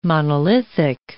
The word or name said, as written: monolithic